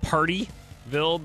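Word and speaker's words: Pardeeville: It's actually, it sounds like Partyville, but it's spelled P-A-R-D-E-E, Partyville.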